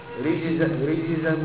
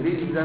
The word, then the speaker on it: رجزا: الجيم تختلط ازاي شويه ريجزن تاجن ضعف الجيم يعني تلاقي كانها ربع جيم كده لا ريجزن من السماء لا عايزين جيم واضح ريجزن واضحه الوحيده كده اهو ما تخليش ازاي تاخد تستني الجيم معاه ولا تلاقيه في الموسم يعني خلي بالك انت لما تيجي تنطق تراجع مع نفسك المراجعه السريعه هتلاقي نفسك بتاكل الجيم كده مع ازاي ريجزن من السماء فأنزلنا عليهم رجزا من السماء رجزا من السماء فين الجيم؟